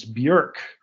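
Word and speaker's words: Björk: And you notice that I call her by her current, you know, her real pronunciation is Bjork, because it rhymes with jerk.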